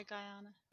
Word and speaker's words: Guyana: Many members of the Temple believe that Guyana, is that how you say it? – Yeah, Guyana.